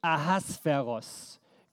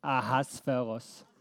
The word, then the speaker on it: Ahasveros: Das ist der Perserkönig Ahasveros. Könnt ihr das aussprechen? Ahasveros.